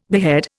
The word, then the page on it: behead: Indian